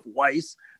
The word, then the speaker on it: Weiss: Joseph Weiss, rhymes with ice,